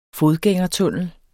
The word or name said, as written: fodgængertunnel